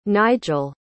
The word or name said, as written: Nygel